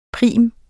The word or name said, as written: prim